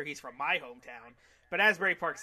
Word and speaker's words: Asbury Park: Asbury Park, baby.